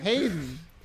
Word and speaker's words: Hayden: I never know how to pronounce his first name. – Hayden. – Hayden? Okay. – Well, go on. I'm sorry. – It's like Aiden with an H.